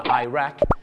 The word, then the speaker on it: Iraq: แต่ชาวอเมริกาเยอะมากครับจะออกเสียงว่า Iraq กับ Iran นั่น